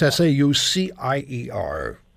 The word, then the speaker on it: Saucier: You did pronounce it correctly. I, I just say Saucier because it makes it a little bit easier right.